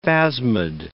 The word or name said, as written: phasmid